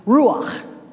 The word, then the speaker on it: ruach: The little dot tells me to use a U sound, and the little dash tells me to use an A sound. So ruach. And ruach, of course, that would be the English kind of pronunciation of the word, right?